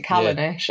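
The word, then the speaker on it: Callanish: How do you pronounce it? Kalanish? – Kalanish. Well, Kalanish. I pronounce it Kalanish.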